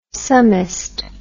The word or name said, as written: summist